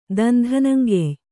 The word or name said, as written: ದಂಧನಂಗೆಯ್